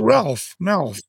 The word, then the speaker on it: Malph: Yeah, M-A-L-P-H. – It rhymes with Ralph Mouth.